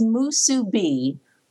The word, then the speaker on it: Musubi: Well, I've heard it as Musubi, but it certainly isn't a capital crime to say Musubi. Either one would suit.